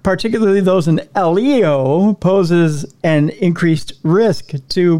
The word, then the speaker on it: LEO: The FAA said in a report to Congress, the dramatic rise of non-geostationary satellites, particularly those in ELO, pose not ELO, but I was going to say, I'm sorry. Electric Light Orchestra. – No, LEO. I said it wrong. LEO, not ELO.